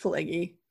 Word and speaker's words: Pileggi: But I'm pretty sure the Mitch Pileggi one was, did I say it right? – No, Mitch Pileggi. – Pileggi. It's a P. Well, I know it's a P, but like I don't know. But it's Pileggi. It's Pileggi. Okay. So Mitch Pileggi.